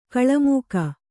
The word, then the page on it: ಕೋಲುಮುಕ್ಕ: Kannada